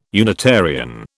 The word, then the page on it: Unitarian: British English